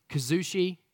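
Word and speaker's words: kuzushi: Some pronounce it kazushi. That's how I pronounce it because I'm part of that some.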